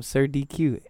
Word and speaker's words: Sir DQ: And I'm Sir DQ everywhere.